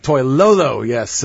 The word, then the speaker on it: Toilolo: There's another Stanford kid, you know, this Levine Toilolo, I think I said it right. But Toilolo. – Toilolo, yes.